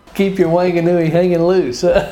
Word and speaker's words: Whanganui: Whanganui, New Zealand. Peter, how did I do on that pronunciation? – Pretty good. Whanganui is the, I think, the proper pronunciation.